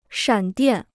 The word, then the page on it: 闪电: shǎn diàn